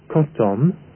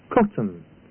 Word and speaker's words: cotton: The word is not pronounced cotton, but cotton. The same is true for the word carbon, spelt C-A-R-B-O-N.